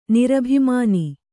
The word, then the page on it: ನಿರಭಿಮಾನ: nirabhimāna